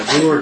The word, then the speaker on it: Agur: All right, I looked up the pronunciation of that. And Agur deals with the idea of trust, no lies, and then we know it's the virtuous woman and the good woman of Proverbs 31.